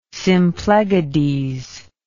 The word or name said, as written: Symplegades